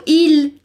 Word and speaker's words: il: Só vamos prestar atenção no artigo il, que tem esse L diferente do que a gente pronuncia no português. Então, dobra a linguinha lá e diz comigo. Il.